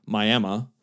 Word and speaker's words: Miami: First of all, the film teaches you the correct way to pronounce the city of Miami, which is Miami,